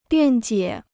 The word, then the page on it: 辩解: biàn jiě